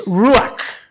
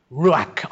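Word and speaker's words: Ruach (רוח): It's Ruach. Ruach. R-U-A-C-H is the way it's spelt in English.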